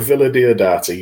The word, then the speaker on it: Villa Diodati: Is that correct? – Yeah, if you take out the 5 second pause between syllables you just gave, the Haunting of Villa Diodati.